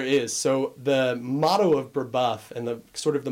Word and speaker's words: Brebeuf: Now, you went to Brebeuf Jesuit Preparatory School.